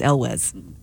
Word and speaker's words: Elwes: which we figured out how to pronounce his name, guys. It's Elwes. So Beau was right the whole time.